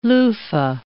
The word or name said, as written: loofah